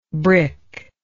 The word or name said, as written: brickish